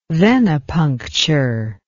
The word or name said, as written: venepuncture